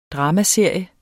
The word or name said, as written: dramaserie